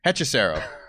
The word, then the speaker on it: Hechicero: Hechicero. – Yeah, close. Hechicero. – That's what I said. – Yeah, but I don't know. You put emphasis on the wrong syllable or something.